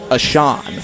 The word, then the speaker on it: Ahcan: His last name is spelled A-H-C-A-N, pronounced Achan, from Savage, Minnesota, USA.